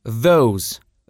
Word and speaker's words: those: И множественное число those. Those. Обязательно запомните, что этот звук под буквой О читается как Оу, а не как О, хотя он тоже довольно короткий при произношении.